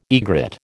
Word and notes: egret: American English

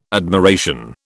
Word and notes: admiration: British English

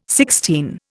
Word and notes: Sixtine: Indian

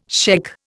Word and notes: shaikh: Indian

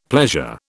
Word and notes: pleasure: British English

